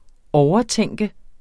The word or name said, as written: overtænke